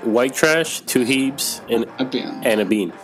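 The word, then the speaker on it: White Trash, Two Heebs and a Bean: ¿Lo dije bien? Eh, white Trash, Two Heaps and a, bean. Ok, sí, es que es bueno tener oh, aquí un, nativo del, inglés. No, sí, está dicho.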